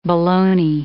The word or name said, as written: boloney